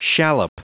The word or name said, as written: shallop